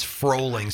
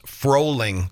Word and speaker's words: Froehling: Last name, thank you, is Froehling, spelled F like Frank, R-O-E-H-L-I-N-G, and it's pronounced Froehling, just like rolling.